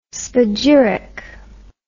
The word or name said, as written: spagyric